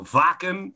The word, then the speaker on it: Wacken: I'd be hands down Wacken Festival, or Wacken, as the Germans pronounce it.